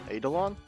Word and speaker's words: Eidolon: Eidolon, by the way. I think it's how it's spelled. Thank you. I actually didn't know how to say it. So it's Eidolon? Okay. Eidolon.